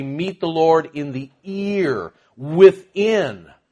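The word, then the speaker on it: ear (ἀήρ): not E-A-R, but Alpha, Eta, Rho, pronounced ear.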